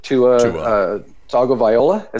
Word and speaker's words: Tua Tagovailoa: Ryan Fitzpatrick is out, and they're bringing in the rookie Tua Tagovailoa.